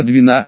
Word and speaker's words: Двина: Еще всех порадовала девочка Двина, ударение в имени было на «и», которая, кроме сестры и брата, с собой захватила двух щенков белой породы.